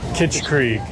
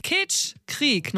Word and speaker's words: Kitschkrieg: The American audience doesn't quite know yet is how to pronounce our name. It's Kitschkrieg. Say it with me. – Kitschkrieg. – Yeah. – Oh, fire. – Kitschkrieg, nochmal zum Mitsprechen.